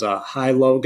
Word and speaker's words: HiLo: So the workout supplement that I wanted to talk about was Hi-Low gummies. It's spelled H-I-L-O, but they're actually gummies that have the same nutritional items as like a pre-workout, but it doesn't have a ton of caffeine.